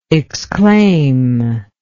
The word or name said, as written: exclaimer